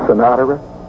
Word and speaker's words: Sinatra: And I said, is it really pronounced Sinatra? And he leaned back and he says, it was when I pronounced it.